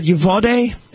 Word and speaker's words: Uvalde: And it's unclear, the last I checked, what you said Uvalde is how it's pronounced,